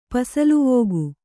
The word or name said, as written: ಪಸಲುವೋಗು